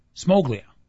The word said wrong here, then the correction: Smoliga: Smoliga? – Mm-hmm.